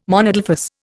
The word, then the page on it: monadelphous: Indian